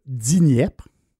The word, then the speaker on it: Dniepr: Après ça, ils découvrent le fleuve je vais le prononcer comme il faut, le Dniepr.